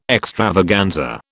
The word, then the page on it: extravaganza: British English